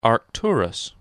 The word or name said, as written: Arcturus